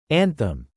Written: anthem

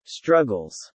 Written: struggles

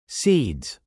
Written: seeds

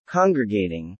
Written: congregating

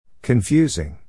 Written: confusing

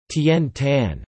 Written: Tian Tan

